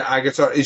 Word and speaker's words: Agutter: This stars a very young Jenny, I can never pronounce her surname, Agutter, I think is how you pronounce it. – Yeah, or Agutter, maybe. – Yeah, Agutter.